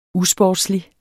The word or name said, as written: usportslig